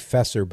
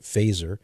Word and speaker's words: Feser: And then lastly, I'd recommend a very good book by Dr. Edward Faeser, F-E-S-E-R. It looks like Fesser, but he pronounces it Faeser.